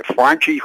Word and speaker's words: Franchy: throwing the ball all around the place, uh, runner getting picked off third base uh, Fra- Fra- how do you say Franchy Cordero? – Uh, Franchy, yes. Franchy.